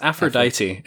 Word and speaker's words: Aphrodite: How do you say it in English? – It's Aphrodite in English.